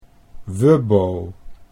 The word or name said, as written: Wubbo